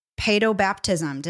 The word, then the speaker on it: paedobaptism: So the next question, can you provide some resources and talk about paedobaptism? Did I say that correctly? – You said it right.